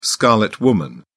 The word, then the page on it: scarlet woman: British English